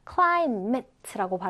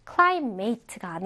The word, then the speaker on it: climate: 자, 지금 보면 climate라고 발음되죠. climate. 자, 따라해 볼까요? climate. climate. climate가 아니에요. mit예요. mit.